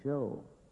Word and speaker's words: shew: Now, most people that know old English know, and I don't think I'm talking down to anybody to say, that when you find that S-H-E-W in the King James, proper pronunciation, show, and it's just an old English spelling of what we mean by the word show.